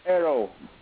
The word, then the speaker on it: perro: That word for dog. Perro. Perro. – Yeah. Yes. That's how you say it. Except Ucho. – You roll your R.